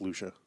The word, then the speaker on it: Lucia: I'm like, yeah, it should be pronounced Lucia. – Right. You're right. It is like Trisha.